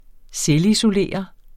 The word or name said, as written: selvisolere